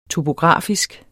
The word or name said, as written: topografisk